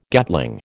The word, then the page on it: Gatling: American English